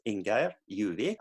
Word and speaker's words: Ingeir Djuvik: Of course. You pronounced my first name very good. My name is Ingeir, Djuvik.